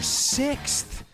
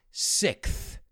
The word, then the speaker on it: sixth: My British friends say this as sixth. So like sick, enfermo, somebody who's not feeling well is sick, and they say it with a TH. So sixth.